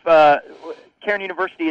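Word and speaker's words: Cairn University: It's pronounced Carn University, so you got it right the second time, which is not too bad. I think it's more times than that. But we have, Carn University has been in existence since 1913, so a little over 100 years.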